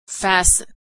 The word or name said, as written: fasten